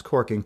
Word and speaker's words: caulking: I mean, it was so common in lumber camps, it picked up the name of corking, which is spelled C-A-U-L-K-I-N-G, like caulk, like a caulk gun, which is pronounced corking.